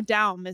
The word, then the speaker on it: Daum: Yeah, lately that's the way I'm pronouncing it again. There's a long and not terribly interesting story. – Oh, sorry. – Actually, so but you're Daum? Right on. Daum? You're very on trend. No, Daum is right. – Okay. – Yes, that's correct.